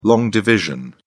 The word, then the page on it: long division: British English